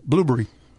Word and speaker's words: Blubrry: And thanks to our new relationship with Blubrry Podcasting, they spell it blueberry without the E's it's almost like blueberry.